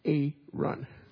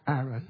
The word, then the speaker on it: Aaron: Where I grew up, this name, Aaron, we used to say A-run. What's the correct pronunciation? Aaron.